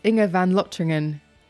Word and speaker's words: Inge van Lotringen: Goodness me, I knew I'd balls it up. Sorry, Inge. But it's Inge van Lotringen.